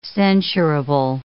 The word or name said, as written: censurable